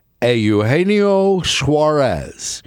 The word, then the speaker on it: Eugenio Suarez: Eugenio Suarez. That's the correct pronunciation of that name.